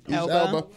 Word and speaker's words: Elba: Elba.